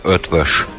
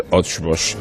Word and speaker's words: Eötvös: Bueno, dicho así, Peter Eötvös puede que no resulte familiar a la audiencia, ni siquiera matizando que las dos O's del apellido llevan encima una diéresis. Así que realmente se pronuncia así. – Peter Eötvös. – Peter Eötvös. Es la manera correcta y académica de mencionar al compositor galardonado por la Fundación BBVA en estos fastos que nos ocupan.